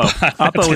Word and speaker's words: Oppo: Oppo is what I like.